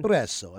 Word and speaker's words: espresso: It's how, in English, how they spell it. It's wrong. You know, they put a E-X. – Yeah, yeah. – Okay. – Espresso. – Exactly. – Yeah. – When in Italian, it's not X. It's only S. E-S-P.